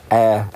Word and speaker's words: Ayr: So, Scotland, Air, A-Y-R, Air, the band.